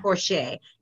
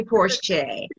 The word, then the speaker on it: Porche: And I don't know if I pronounced it right, but the last name is P-O-R-C-H-E. – Yeah, Porsche. Yeah. – Porsche.